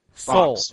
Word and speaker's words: Soule: Soul. It's Soul. I've told you this whatever. – So many times. It's Soul. – But it has an E at the end. It doesn't. – The E is silent.